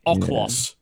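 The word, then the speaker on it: Okhlos: How do you pronounce it? It begins with an O. It's Oklos.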